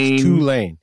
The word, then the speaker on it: Tulane: All respect to Tulane. Got to say it right. – Tulane.